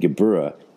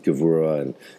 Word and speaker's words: Gevurah: That is not correct, because you don't know Hebrew, and Geburah is how that word was said up until the 20th century when Hebrew significantly changed and Geburah became pronounced Gevurah, and the whole pronunciation system shifted over by a very common linguistic change in consonantal sounds.